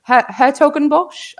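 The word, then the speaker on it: 's-Hertogenbosch: Hertogenbosch, I think the S is silent.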